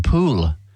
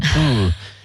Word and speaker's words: Poole: And Christchurch, yeah, and Poole around that area. You're looking at those words like, how do I pronounce that? Poole. – Yeah. – Pool, P-O-O-L-E is pool.